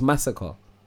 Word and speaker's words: Masicka: So how do you pronounce his name? – It's spelled M-A-S-I-C-K-A. – Okay, but everyone calls him Masika. – But English people that don't know how to say it call him Masika, because that's okay. How it's spelled. But it's Massacre.